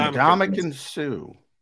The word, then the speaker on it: Ndamukong Suh: Help me out with Sue's first name. I'm pronouncing Ndamukong Suh.